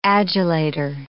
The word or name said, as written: adulator